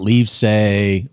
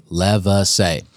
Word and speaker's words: Lievsay: That's how you pronounce it, folks. So get it right next time you introduce him. It's not Lievsay, Lievsay.